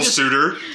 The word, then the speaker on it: Suter: And it's not even spelled like S-U-D-E-R nope. Like you think. No, it's spelled Suter, as in, like, yeah. You know, want to go a-courtin'. – Yes. – I'm like, this is the general suitor.